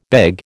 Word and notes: beg: American English

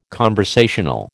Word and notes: conversational: American English

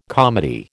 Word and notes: comedy: American English

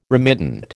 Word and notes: remittent: American English

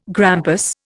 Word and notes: grampus: Indian

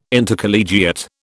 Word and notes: Intercollegiate: British English

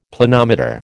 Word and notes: Planometer: American English

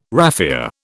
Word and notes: raphia: British English

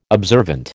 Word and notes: observant: American English

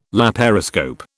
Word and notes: laparoscope: British English

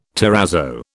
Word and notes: terrazzo: British English

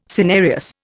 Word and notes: cinereous: Indian

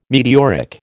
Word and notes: meteoric: American English

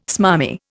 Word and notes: smarmy: Indian

